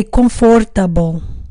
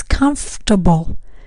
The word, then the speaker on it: comfortable: So sometimes students say comfortable, wrong, careful with that pronunciation. It's comfortable. Comfortable.